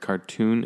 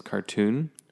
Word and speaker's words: cartoon: This one is cartoon, where you do pronounce the T. Cartoon and carton, like a carton of milk. Right, but this is cartoon.